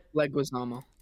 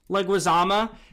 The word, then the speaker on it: Leguizamo: Leguizamo. – Leguizamo, his house.